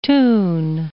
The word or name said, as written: toon